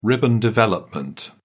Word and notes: ribbon development: British English

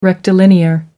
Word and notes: rectilinear: American English

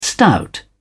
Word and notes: stout: British English

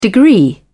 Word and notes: degree: British English

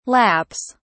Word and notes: laps: British English

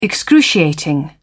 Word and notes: excruciating: British English

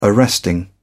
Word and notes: arresting: British English

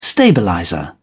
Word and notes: stabilizer: British English

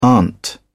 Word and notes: aunt: British English

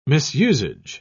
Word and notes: misusage: British English

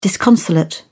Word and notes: disconsolate: British English

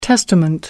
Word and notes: testament: American English

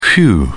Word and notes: phew: British English

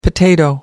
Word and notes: potato: American English

